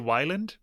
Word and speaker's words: Weiland: I say Weiland because the word is spelt as a Dutch word and you pronounce it Weiland.